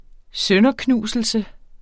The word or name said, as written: sønderknuselse